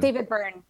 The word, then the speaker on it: David Byrne: Yeah, I can never say his last name right, but he's the lead singer of the Talking Heads, David Byron. – It's not Byrne? I always thought it was Byrne. – David Byrne.